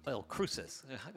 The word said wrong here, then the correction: El Cruce: Is that how you say it? – Sort of. – Sort of? – Yeah. – Come on, help me with the Spanish here. – El Cruce.